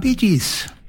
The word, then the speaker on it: Bee Gees: Och så har du att du skriver det så du uttalar det, så blir det Bee Gees.